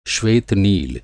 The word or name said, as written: नील